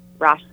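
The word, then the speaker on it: Roshani: And weirdly enough, there's like a silent A snuck in there. So I appreciate the effort, but it's actually pronounced Roshni.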